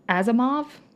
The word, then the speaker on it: Asimov: but Isaac Asimov, is that how you say his name? – Sure.